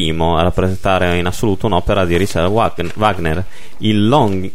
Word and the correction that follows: Lohengrin: il Lohengrin. – Lohengrin. Lohengrin. Lohengrin. Eh, vedi, tu c'è la pronuncia migliore, io sono, devo ancora imparare tanto.